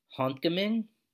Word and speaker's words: Håndgemeng: and the name of the band is pronounced Håndgemeng, spelled H with an A and a dot over it, N-D-G-E-M-E-N-G, and yeah, so they're from Norway.